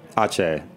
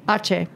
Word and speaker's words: Aceh: Aceh. – Aceh.